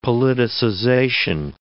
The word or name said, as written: politicization